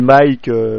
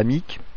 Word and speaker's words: Mike: Oui, Mike, faut bien prononcer Mike et non pas Mick.